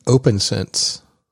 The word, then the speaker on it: OPNsense: I think that's supposed to be pronounced OpenSense. – Is it OpenSense? Oh yeah, it would, not OPNSense.